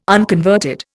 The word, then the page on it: unconverted: Indian